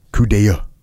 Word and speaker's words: coup d'oeil: It looks like coupe d'oeil, but the way you actually say it is coup d'oeil.